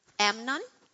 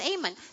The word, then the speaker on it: Amon: So in English, I was talking with my friend and she said, Amnon? Am I saying right? Kind of, how do you pronounce this name? Tell me. Amon? Oh, it's Amon.